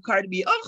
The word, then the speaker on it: Cardi B: In the words of Cardi B.